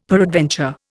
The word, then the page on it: peradventure: Indian